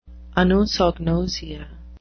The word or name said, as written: anosognosia